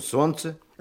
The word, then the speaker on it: солнце: Так и слышим «солнце». – А ведь в середине этого слова есть еще буква «Л», только она не произносится.